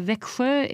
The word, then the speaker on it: Växjö: Uh, Växjö, it's V, uh, an A with two dots on it, X.